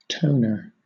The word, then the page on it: toner: British English